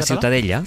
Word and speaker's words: Ciutadella: La Ciutadella.